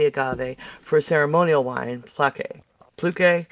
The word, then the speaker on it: pulque: Pulque.